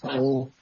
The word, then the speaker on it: Xanh: There's also Grab, and it's pronounced Thang. You can call it Green. It's XANH. That's all of the VinFast cars.